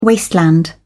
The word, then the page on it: wasteland: British English